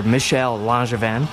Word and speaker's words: Michel Langevin: But yeah, basically Michael Langevin, or Michel Langevin, for all of you hardcore Frenchies out there that would be all over me for mispronouncing his name,